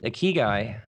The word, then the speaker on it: ikigai: It's a Japanese word, ikigai.